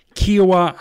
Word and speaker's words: Kiawah: But let's start with the 2021 PGA Championship, which is at Ocean Course in Kiawah Island, South Carolina.